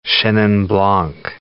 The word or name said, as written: chenin blanc